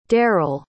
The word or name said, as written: daryle